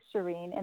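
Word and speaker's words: Sherene: So it's S-H-E-R-E-N-E, Shireen, and then Vishmaya, V-I-S-M-A-Y-A.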